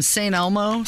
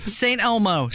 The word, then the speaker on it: St. Elmo: Thank you for saying it right, because I don't want the mean tweets to come in. I can't believe they put an S at the end of it.